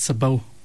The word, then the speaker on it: sabot: So the correct answer that we were looking for, Marana, is the word sabot. The word sabot. It's spelled S-A-B-O-T, but the T is silent. S-A-B-O-T, sabot.